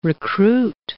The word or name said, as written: recruit